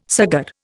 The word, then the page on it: saggar: Indian